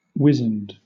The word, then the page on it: wizened: British English